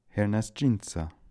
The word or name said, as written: Hernasdjinsa